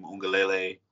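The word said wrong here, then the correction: Uiagalelei: Ui Angalele. – Angalele.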